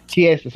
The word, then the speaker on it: Chiesa: Even in that fight against Kaseya, I keep saying his name wrong. He didn't really put combination. Yeah. The one he fought this week, Michael Kaseya. Is it Kaseya? Chiesa, sorry.